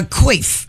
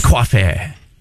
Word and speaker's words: coiffé: A coif. Is a coif? – It's coiffé. You got to pronounce it like a Frenchman.